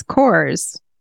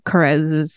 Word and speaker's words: Korres: Okay, so I smell like Carez, K-O-R-R-E-S, Saffron Orris. – Is that how you say that? It's Carez? – Yeah. Carez. I remember because in my head, it's Coors, because that's the English pronunciation.